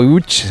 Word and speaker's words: Łódź: Aunque se pronuncia Łódź, se escribe Lodz, por si alguien lo busca Lodz, en Google. Casi no tiene nada que ver la pronunciación.